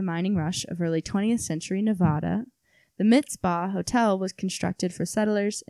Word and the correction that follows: Mizpah: Mizpah. I might not be saying that right. M-I-Z-P-A-H. – Mizpah.